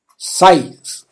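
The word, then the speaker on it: Sigh: Dessa vez eu vou escolher a música Sai. Essa, é, essa, essa palavra sai ela significa suspiro no singular, tá? O GH não se pronuncia, é como se a gente falasse o verbo sair em português.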